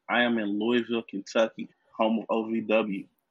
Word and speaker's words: Louisville: It's Louisville. – Okay, Louisville. They got put too much emphasis on the Louis. It's just Louisville. Just let it flow.